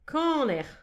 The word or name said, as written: kaner